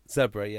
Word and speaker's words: Zebre: Then Zebre. I think I'm saying that right. Mm-hmm. Host Connacht. – Hang on. Zebre. Yes.